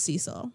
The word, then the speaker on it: Cecil: I would not call it the Cecil. I feel like I pronounce words wrong all of the time, but I feel confident this is Cecil.